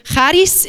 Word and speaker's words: charis: And the word favor, it comes from a Greek word, and it's charis, but if you speak Greek, if you know anything about biblical Greek, that charis is how you pronounce it, and it's the same way that you say in the word evcharisto, which is how you say thank you in Greek.